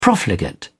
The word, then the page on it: profligate: British English